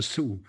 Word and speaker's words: Sioux: Da habe ich gesagt, die spricht man aber anders aus. Da sagt man nicht Sioux, sondern Sioux.